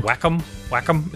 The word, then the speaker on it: Wacom: You know, I don't even remember what the reason was, but I have a it's called a Wacom tablet. Are you aware of these? – No. – Wacom. It's spelled... The company is spelled W-A-C-O-M.